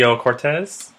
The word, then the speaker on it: Ocasio-Cortez: Democrat Alexandria, how do I pronounce her last name? Ocasio-Cortez.